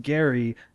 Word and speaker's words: Gerry: Gerrymandering is how it was originally called because a congressman with the last name Gerry, pronounced Gary, made a political district that looked like a salamander.